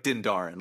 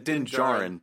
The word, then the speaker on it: Djarin: He was basically Boba Fett, but if Boba Fett was a sniper, or he's basically like Din Djarin, Din Djarin. Din Djarin. I always get confused which of those two letters to pronounce, the D or the J.